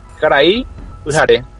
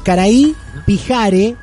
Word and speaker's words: Karai Pyhare: Caraí Ujaré. – Eh, Caraí Pijare se escribe P-Y-H-A-R-E.